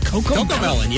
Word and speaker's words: Cocomelon: You think the Cocomelon thing is, am I saying it right? Cocomelon, yeah.